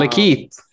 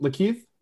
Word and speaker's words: Lakeith: Yeah, apparently that guy, that Lakeith Stanfield, he was in a uh, um, Lakeith. – Lakeith, right? Yeah. How do you pronounce it? Lakeith. – Yeah.